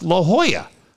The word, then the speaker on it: La Jolla: It also helps after hearing Jeff talk about the book and how to pronounce La Jolla. I'd be saying it with the hard J for sure.